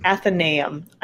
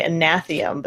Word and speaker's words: Athenaeum: Say it again. Athenaeum. – Athenaeum. – I always want to say Anathium, but that's a totally different thing.